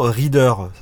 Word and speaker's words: Reeder: J'utilise la majorité du temps Reader. Ça s'écrit R-E-E-D-E-R.